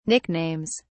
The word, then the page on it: nicknames: British English